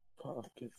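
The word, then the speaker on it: Pokkén: That's how it's pronounced. – Pokken. – There's two K's. – Pokken. – No, there's Pokken. Two K's. – Pokken. Tekken. Pokken. It's not called Takken.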